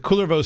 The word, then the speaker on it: Kullervo: So the Väinämöinen and Jukkahainen story is from runos 3 and 4 of the Kalevala, and the Kullervo story, which you'll hear us pronounce it both Kullervo and Kullervo, basically depending on the meter of the line. The Kullervo story is found in, but I've read that apparently in Finnish it is Kullervo, with a stress on the first syllable.